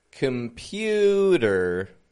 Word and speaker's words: computer: Listen for the syllable stress in this word here. Computer. Which is the stressed syllable? The middle. The pew. Pew. Computer. The C-O-M isn't getting a com. It's getting just a com. Com. There's a schwa in there. Instead of a regular O. Com.